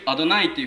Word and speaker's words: アドナイ: その代わりにアドナイっていう、ヘブライ語でその主っていう言葉、英語だと Lord っていう、私の主人っていう言葉です、主人。ロード・オブ・ザ・リングじゃないですけど、ロード、道の R のロードじゃないですよ、L の方ね。で主、私の主ですっていう、その主アドナイっていうふうにユダヤ人たちは発音します。